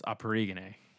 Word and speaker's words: Akporoghene: The K in his name is silent, so it's Akporoghene,